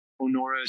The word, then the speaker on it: Onora: Onora is spelled O-N-O-R-A. It's a play on the word honor.